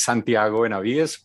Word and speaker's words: Santiago Benavides: Well, my name, as you said, is Santiago Benavides. Very well pronounced, Chris. Thank you for that. I am kind of getting used to be Santiago Benavides and many other possibilities here.